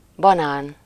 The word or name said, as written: banan